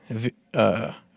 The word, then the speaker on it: Hvergelmir: The second well, this is according to Snorri Sturluson's Prose Edda, is called Hvergelmir. Hvergelmir.